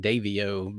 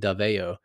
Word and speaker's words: Davao: With my American accent, it'd be like Davio, but it's probably more like Davao.